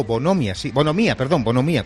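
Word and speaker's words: bonhomía: Luego está la palabra bonomía, con H intercalada entre la N y la O. Bonomía, con acento en la I.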